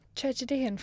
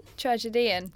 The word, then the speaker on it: tragedian: The ancient Greek tragedian. Tragedian. Fuck, how do I even pronounce that? – Tragedian. – He wrote tragedies. – Oh, tragedian.